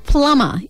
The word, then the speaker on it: plumber: Gunners, the word plumber is spelt P-L-U-M-B-E-R. Now, it has a silent B in there because it comes from the Roman Latin word plumbum, which means lead piping used by plumbers.